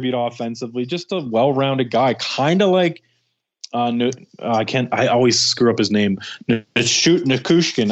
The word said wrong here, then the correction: Nichushkin: I can't. Whenever it comes out of my mouth, I completely butcher it. – Val Nichushkin. – There you go, Nichushkin.